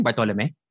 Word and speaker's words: Bartolome: Bartolome.